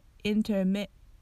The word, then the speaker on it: intermittent: この intermittent も同じで tt の発音が消えて intermittent となります intermittent アメリカのネイティブっぽい発音に寄せていきたい人はぜひ真似してみてください Alright, repeat after me Is the pain persistent or is it more intermittent?